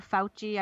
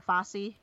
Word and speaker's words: Fauci: Like Dr. Fauci, I thought it was Dr. Fossey forever.